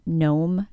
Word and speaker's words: gnome: Other digraphs that can mask themselves as silent letters include GN being pronounced as the N sound at the beginning of a word, as in gnome, gnat, and gnaw,